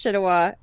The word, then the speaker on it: Chenoa: So, subtitles don't help me much in that regard, but Google tells me that the name is pronounced Chinua from it's a Spanish origin, so we're gonna go with that.